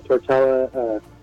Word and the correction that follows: Tortorella: How would you say his name? Tortella. John Tortella. – John Tortorella.